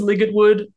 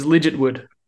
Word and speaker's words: Ligertwood: I thought it was Ligetwood but it is not. It's legit wood. – Okay. Legit wood. so it's like legit wood almost.